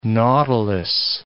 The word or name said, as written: nautilus